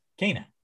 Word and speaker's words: Kena: Kena. Sorry, not Kena. Kena. – Kena. – It's pronounced Kena.